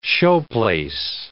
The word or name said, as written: showplace